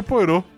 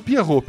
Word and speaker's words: Poirot: É, como é que vocês pronunciam o detetive da, da, da Agatha Christie? Poirot? Poirot? Poirot, né? Eu acho que. É, eu sempre falei Poirot. Eu já ouvi gente falando Pierrot. Pierrot tá muito errado.